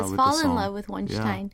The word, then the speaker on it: Wonstein: I always call him Wonstein. – Wonstein, yeah. – Which is the Korean pronunciation.